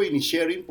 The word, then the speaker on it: Inisherin: Yo digo Inisherin porque supuestamente es lo más cercano al gaélico, pero Rafa Sarmiento dice Inisherin, que también puede ser más cercano al gaélico.